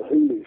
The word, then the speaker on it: Huglu: I mean, the Hulu factory, that's spelled Huglu, H-U-G-L-U, they produce some very good side-to-side and over and under guns at attractive prices.